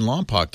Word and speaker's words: Lompoc: 8888-ASK-LEO, that's the phone number, line 2, Jay in Lompoc, California. Hi, Jay. – Oh, hi, Leo. That's Lompoc. – I know, I always say it wrong. Lompoc. – W.C. Fields pronounces it Wampak.